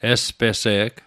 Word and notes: espese’g: Mi'gmaq

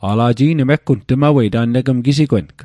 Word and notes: gisigweng: Mi'gmaq

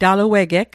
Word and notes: taluegeg: Mi'gmaq

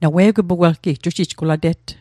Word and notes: jujij: Mi'gmaq

